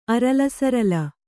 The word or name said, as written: ಅರಲಸರಲ